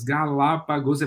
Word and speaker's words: Galápagos: mas é um nome de quatro sílabas, Galápagos, é proparoxítono.